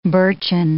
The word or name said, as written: birchen